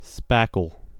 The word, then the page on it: spackle: American English